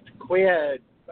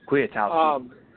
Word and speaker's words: Kwiatkowski: I know some of that has changed a little bit with the loss of both, well, of Jarrell Freeman and Nick Quaid, I can't pronounce his name. – Kwiatkowski.